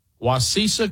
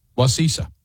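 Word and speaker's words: Wacissa: Wasisa Christian Church. – You got a feeling that's not right. – No, I think it's right. Wasisa. That's what the locals say.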